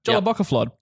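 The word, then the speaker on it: Jólabókaflóð: That's not how you pronounce it. – Yes. – Great. – Jolla Bocala Flod. Jolla Bo Ka Flod. There you go. Jolla Bocca Flod. – That's the one.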